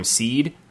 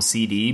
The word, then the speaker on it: SeeD: I've started calling him Seed. I used to call him CD because the D is capitalized, but I call him CD.